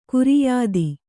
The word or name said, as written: ಖೈರಿಯತ್ತು